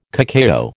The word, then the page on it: cacao: American English